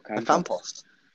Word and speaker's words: Ocampos: Ocampos.